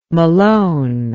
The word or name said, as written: malone